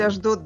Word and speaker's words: Ашдод: Есть Ашдод, есть муниципалитет. – Ну, в общем, да, есть Ашдод, да, присутствует такой город на Ближнем Востоке, действительно.